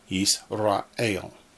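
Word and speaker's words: Yisrael (ישראל): Yisrael. Three syllables.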